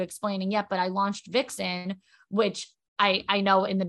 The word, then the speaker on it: Vicsen: I'm like, so it's Vixen, like V-I-X-E-N, except it's me, V-I-C. S-E-N.